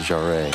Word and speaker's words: Jarre: Jarre or jar. – I'd always pronounce it Jarre.